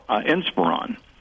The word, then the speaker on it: Inspiron: The Dell Esperon, I guess that's how it's pronounced. Dell Inspiron, a desktop AMD.